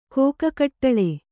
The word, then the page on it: ಕಿಗ್ಗಡಲು: kiggaḍalu